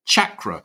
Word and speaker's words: chakra: I've been mispronouncing the word chakra. I say chakra as though it's spelled SH, but of course there we heard Mars Sector 6 say chakra.